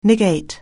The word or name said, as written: negate